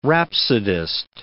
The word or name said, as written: rhapsodist